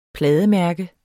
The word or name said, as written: plademærke